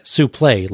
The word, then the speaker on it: suplex: suplex. Vern, by the way, pronounces it suplay like Gordon Solie does.